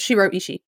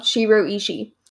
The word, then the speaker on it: Shiro Ishii: And as I said, the number of experiments that were done and just the fact that this, honestly, I feel like this guy Ishishiro, is that how you pronounce it or how you pronounced it? – Backwards. Shiro Ishii. – Oh, Shiro Ishii.